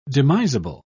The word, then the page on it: demisable: British English